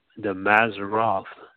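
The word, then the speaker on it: Mazzaroth: But in ancient times, they were referred to as the, because I'm going to pronounce this name wrong, but it's the Maseroth, and that's M-A-Z-Z-A-R-O-T-H. That's M-A-Z-Z-A-R-O-T-H.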